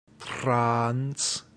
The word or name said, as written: trans